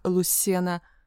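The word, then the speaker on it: Lucena: Я родилась в Лусене, это произносится как Луссена, Кордова.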